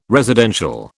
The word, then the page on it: residential: British English